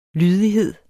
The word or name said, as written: lydighed